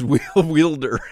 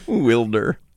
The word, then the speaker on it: Wilder: To mispronounce Wilder as Wielder. That's a funny mispronunciation. Wilder.